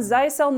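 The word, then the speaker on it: Zyxel: If you own Zycel networking equipment, and yes, that is how it's pronounced, they actually have a music video on their own YouTube channel dedicated to the pronunciation.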